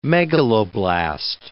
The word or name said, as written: megaloblast